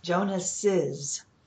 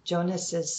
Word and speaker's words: Jonas's: So we need to add a syllable. Jonas's had been called a bear, Jonas's stuffed animal. So when you have a word that ends with a syllable S and you add the apostrophe S, you need to add is, an extra syllable.